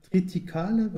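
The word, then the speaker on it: Triticale: Kritikale, wird das so richtig ausgesprochen? – Ja, es ist auch, aber das kommt bei uns eher selten vor.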